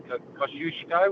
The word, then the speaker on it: Kosciuszko: And then we did a walk from Thredbo up to top of Kosciuszko, was the correct wording, I believe, correct pronunciation.